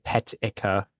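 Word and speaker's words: peteca: It's so Portuguese pronunciation, petica.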